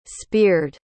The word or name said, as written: spiered